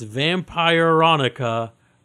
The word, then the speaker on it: Vampironica: Let me try to pronounce this correctly. Vampronica. That's Vampironica. A vampire version of Veronica. – Vampironica? – Vampironica is the better way of pronouncing it. Yes.